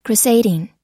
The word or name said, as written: crusading